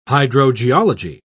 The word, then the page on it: hydrogeology: British English